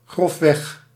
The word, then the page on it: roughly: /ˈɹʌf.li/